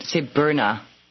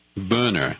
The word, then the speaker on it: Boehner: If you said it in German, you would call say Boehner. – Boehner. – Boehner. Uh-huh. It's like an O umlaut.